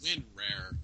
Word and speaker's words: WinRAR: Rinrar. – Rinrar. And how furs unzip stuff. – I'm calling it Rinrar, but it's pronounced Winrar.